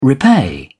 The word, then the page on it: repay: British English